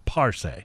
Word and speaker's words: Parce: It's Parce, emphasis on the first Parce.